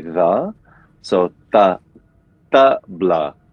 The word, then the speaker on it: tabla: So tabla, is it, so the pronunciation, I want to make sure I'm saying everything right. So tabla, is it tabla or tabla? – It's the tabla.